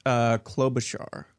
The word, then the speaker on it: Klobuchar: I've lived in Minnesota, and I mispronounced it and been corrected. Klobuchar.